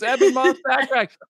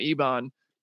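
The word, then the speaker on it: Ebon Moss-Bachrach: So we have the cast, and we now know how to say their names. Eben Moss Backrack. I think I saw somebody somewhere, like on a very public forum, I forget what it was, called him Ebon, and they were like instantly corrected to Eben on the show I was watching.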